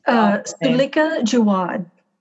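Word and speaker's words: Suleika Jaouad: Sulika Jawad.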